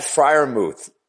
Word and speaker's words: Freiermuth: How do you say, 87's name? Freeburg? – It's Pat Freiermuth. Exactly how you said it, David.